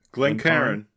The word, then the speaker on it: Glencairn: Glencairn.